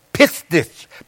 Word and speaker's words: pistis: The Greek word for faith is, I don't know how to pronounce it right, pistis, P-I-S-T-I-S, P-I-S-T-I-S.